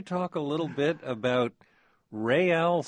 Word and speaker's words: Real: by calling it Real, R-E-capital-A-L, so that everybody out there pronounces it correctly.